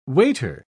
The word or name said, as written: waiter